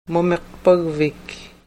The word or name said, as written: mumiqpagvik